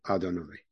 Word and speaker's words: Adonai: we actually pronounce it Adonai.